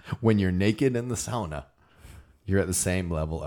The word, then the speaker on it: sauna: Okay, so the same kind of thing with the sauna, and this is pronounced sauna. Sauna? Sauna. Sauna. That's how they pronounce it.